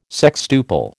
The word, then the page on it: sextuple: American English